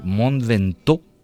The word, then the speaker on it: Mont Ventoux: Silvia Alcaina es murciana y va a afrontar el reto de la ascensión al Mont Ventoux, que se dice así en francés, ¿verdad, Silvia?